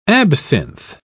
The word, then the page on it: absinth: British English